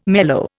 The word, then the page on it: mellow: Indian